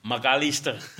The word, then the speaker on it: McAllister: En Wil je nog één keer de achternaam uitspreken zoals ze het in Argentinië doen? – McAllister. – Heel goed.